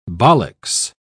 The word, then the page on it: bollix: British English